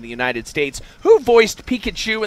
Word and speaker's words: Pikachu: Am I saying that right, Kevin? – Pikachu.